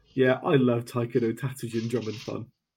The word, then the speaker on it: Taiko no Tatsujin: Uh, Taiko no Tatsujin, I think I pronounced that correctly.